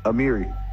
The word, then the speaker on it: Amiri: Amiri. – Oh, Amiri, like the clover line.